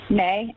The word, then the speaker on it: Mae: May, M-A-E. – And your last name, May? – Ismael, I-S-M-A-E-L.